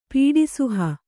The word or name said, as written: ಪೀಡಿಸುಹ